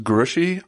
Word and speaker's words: Grouchy: It is pronounced Grushy, although it is spelled Grouchy.